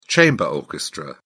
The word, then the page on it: chamber orchestra: British English